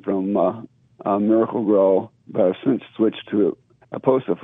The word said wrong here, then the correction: Espoma: for this year. Aposa. I can't pronounce it properly. – Oh, Espoma. – I switched it. Yeah, Espoma.